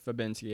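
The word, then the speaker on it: Fabiński: But we will move on, though, to Andre Muniz versus Bartosz Fabinski. Did I say that right? I think I did. – Yeah, Muniz versus Fabinski, yep.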